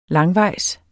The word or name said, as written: langvejs